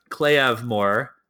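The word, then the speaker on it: claidheamh mòr: I loved hearing you guys talk about the two-handed claymore from the Gaelic Clay de Moore, pronounced Clay of Moore.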